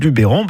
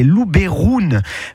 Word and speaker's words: Luberon: En fait, en provençal, le E se prononce E. Donc, si vous dites Luberon, ben, c'est presque comme la prononciation d'origine de cette région. Je m'explique. La prononciation d'origine en provençal, c'est Luberoun.